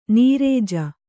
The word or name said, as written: ನಿರಜ